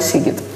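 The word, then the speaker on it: Sziget: Короче, мой любимый фестиваль Сигит. Так. Его зачем-то называют Зигит, потому что он пишется через Z, но в венгерском языке Z читается как S, и там еще огромное количество других подстав с буквами.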